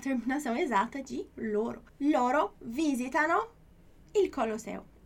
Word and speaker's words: visitano: Olha aí a pronúncia de novo, mudando um pouquinho, né? Em português a gente só tem as três últimas sílabas da palavra, né, para ter acento. Em italiano a gente tem até uma quarta sílaba, tendo um som mais forte, né? Então é visitano, não é visitano, ok?